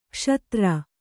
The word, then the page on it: ಕ್ಷುದ್ರಿ: kṣudri